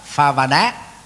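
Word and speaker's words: bhāvanā: Thì trong tiếng Pali và Sanskrit có đọc là Phavana.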